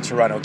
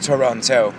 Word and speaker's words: Toronto: Toronto, Canada. Or if you're not from here, it's Toronto.